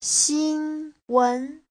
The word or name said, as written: xin1wen2